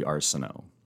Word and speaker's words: Arceneaux: So it's actually Arceneaux. It's Acadian French. And the worst pronunciation is probably, you may not be old enough to remember them, but I've been called Arceneal, like Arceneal Hall before.